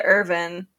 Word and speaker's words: Erwin: Is it Irwin or Irvin? They pronounce it Irvin, but in the subsection, it's spelled E-R-W-I-N, and so I just pronounce it as American as possible, of course, and because I'm a very phonetic reader.